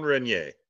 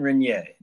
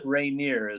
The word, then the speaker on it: Reynier: Jean Reynier, not Reynier, as I call him. – Yeah. You know, well, I hear you. You're in English pronunciation and yes. The French would say Reynier, but Reynier.